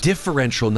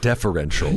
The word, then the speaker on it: differential: Differential, not deferential.